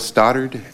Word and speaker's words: Stoddard: It's Detective Phil Stoddard, S-T-O-D-D-A-R-D.